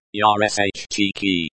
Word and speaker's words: ящике: Yashiki is the noun drawer, pronounced like yarsh cheeky. The e at the end is very subtle. The sh is pronounced like the sh in ship.